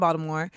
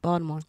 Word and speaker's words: Baltimore: you're somebody who came out to LA from Baltimore. – Mm-hmm. You went to Thank school. Thank you for, for, saying it right, Baltimore.